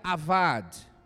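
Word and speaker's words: avad (עבד): Cultivar no hebraico, que é o que está em Gênesis, se pronuncia avad, que é o termo que nós lemos aqui, cultivar.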